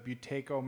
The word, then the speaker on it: Buteyko: So you mentioned the Buteyko method. Is that pronounced correctly? – Yeah.